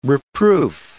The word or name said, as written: reproof